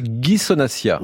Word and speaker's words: Ghisonaccia: C'est Ghisonaccia.